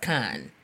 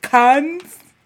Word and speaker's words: Cannes: I think it's pronounced Cannes. – It's oh. French. Well, bitch, I'm I'm Jabari Tribe. I didn't know. – Sorry. – Uh, okay. So cons. – Con. Con. So you don't you don't pronounce the es at all? No, I don't think so.